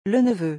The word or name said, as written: Le neveu